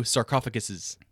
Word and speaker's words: sarcophagi: What is the sarcophagi? How do you say it? – Sarcophagi. – Okay, cool. – Or you can do sarcophaguses. – Sarcophaguses? That sounds horrible. – It's both. But sarcophagi sounds better.